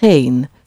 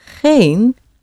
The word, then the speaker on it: geen: And to say, I have not a friend, not any friend, or no friend, you say geen. So een is one and geen is not one. Geen, not any or no. Okay? And it's spelled G-E-E-N, just een with a G in front of it. Geen.